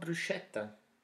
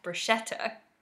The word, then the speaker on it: bruschetta: And what about bruschetta? – Ah, yeah. So in English, we often pronounce it as bruschetta, but in Italian, CH is pronounced as a hard K sound. So it's actually bruschetta.